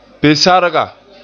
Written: pe-sarga

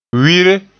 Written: wire

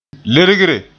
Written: lergre

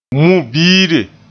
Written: mu-biire

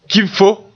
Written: kibfo

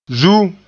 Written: zuum

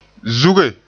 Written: zuge

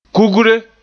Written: kugre

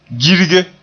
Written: girge

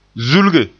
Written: zulge

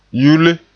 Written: yule

